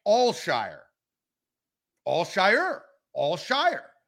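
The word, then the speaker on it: Al-Shaair: There's less emphasis on the ear at the end. It's Al Shire.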